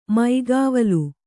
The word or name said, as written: ಮೈಗಾವಲು